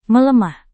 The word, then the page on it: melemah: Indonesian